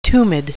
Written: tumid

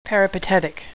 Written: peripatetic

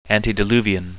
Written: antediluvian

